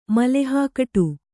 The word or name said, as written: ಮಲೆಹಾಕಟು